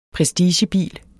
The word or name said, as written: prestigebil